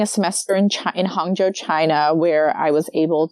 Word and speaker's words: Hangzhou: Is that how you pronounce it? – Hangzhou, yeah. Hangzhou, okay.